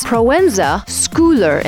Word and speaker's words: Proenza Schouler: It's actually one of the most mispronounced or hardly pronounced brands because of its SCH in its Schouler. So it's Proenza Schouler instead of Proenza Schouler that some people might think.